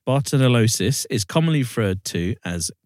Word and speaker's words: Bartonellosis: And it is, I can't pronounce it properly, it's Bartonellosis. That's how it's pronounced. – Bartonellosis.